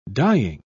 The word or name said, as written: dying